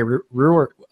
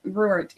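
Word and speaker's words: Ruwart: I have Dr. Mary Ruhr, excuse me, I mispronounced the name, Dr. Mary Ruhr coming on this week.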